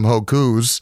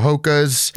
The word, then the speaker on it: Hokas: I think last time I didn't even know how to pronounce them. I think I was calling them Hokus. Mm. So since then, I have bought a pair of Hokas.